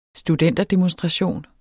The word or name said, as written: studenterdemonstration